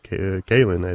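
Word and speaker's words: Kahlan: Even when I was reading Good Kind Shitty Sword of Truth near the series, I was like, how do you pronounce, is it Colin? It's kind of spelled that way. And then you watch the TV show Kalen, or you go to his website. No, it's pronounced Kalen, I think.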